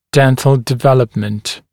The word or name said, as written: dental development